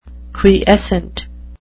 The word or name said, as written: quiescent